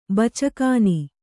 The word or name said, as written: ಬಚ್ಚಗಾನಿ